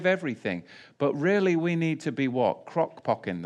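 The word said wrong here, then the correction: crock-potting: the... or whatever, that was a Freudian slip. how do we say it? What is it? Crock potting.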